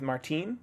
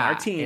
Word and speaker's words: Martine: He likely figured it out, and I like this mirroring with Martine, not Martin, how it's spelled. – Yeah, Martine. It's Martine.